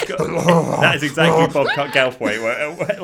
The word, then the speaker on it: Goldthwait: And they answer the phone to what I believe to be Bobcat Goat. I can't say his name. Bobcat Goldthwait, or whatever his name is, from Police Academy 2.